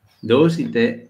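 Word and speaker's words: doshite (どうして): Entonces, doshite, porque la I está no se pronuncia tanto.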